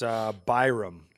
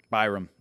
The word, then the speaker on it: Byram: It's Byram. – Byram.